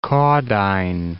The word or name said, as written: Caudine Forks